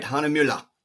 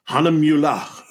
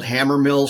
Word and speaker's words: Hahnemühle: You said it right, Hahnemühle. Hahnemühle.